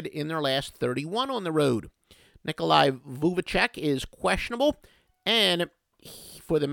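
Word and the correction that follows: Vučević: Magic, but they are 3 and 1 without him going under 3 and 1 that's Vucevic. I'm sorry, is how you actually pronounce his name. Yes, I do watch NBA games. Vucevic is how you pronounce it.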